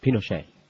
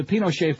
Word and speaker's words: Pinochet: Pinochet. – No, but actually, they always say Pinochet, which I don't know, because I know that's not how you would say it in Espanol. But anyway, The Pinochet File by Peter Kornbluh of Washington's Investigative National Security Archives.